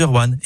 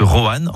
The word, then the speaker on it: Rouen: Vous avez remarqué comment je dis Rouen en une syllabe plutôt que Roanne en deux syllabes ? C'est comme ça qu'on y dit dans la région.